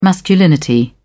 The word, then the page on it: masculinity: British English